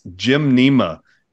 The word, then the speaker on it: Gymnema: It's actually, it's pronounced like gymnasium, but it's Gymnema, G-Y-M-N-E-M-A, Gymnema.